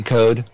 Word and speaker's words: Code: Did I say your last name correct? – Yeah, you did. Yeah, just like it looks, C-O-D, code. – Okay. – It's apparently it's Irish.